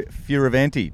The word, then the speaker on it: Fioravanti: Thanks for joining me, Leo Fioravanti.